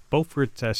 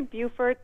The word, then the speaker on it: Beaufort: Let's go to Beaufort, South Carolina. Hi. – Hi, thank you so much for having me. It's actually Beaufort, but we're way deep in the South, so don't worry about the pronunciation.